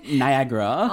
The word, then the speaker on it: Niagara: 나이아가라. – 아, 나이아가라. 나이아가라. 아, 그거 본토 발음으로. 네. – Niagara.